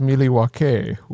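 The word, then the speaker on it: Milwaukee: Yes, it's actually pronounced Miliwake, which is Algonquin for the good land.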